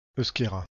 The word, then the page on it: euskera: /euʃ.ke.ʁa/